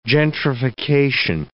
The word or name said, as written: gentrification